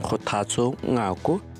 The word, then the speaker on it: Kgothatso Ngako: Hello everyone, I'm Kgothatso Ngako from South Africa.